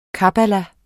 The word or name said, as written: kabbala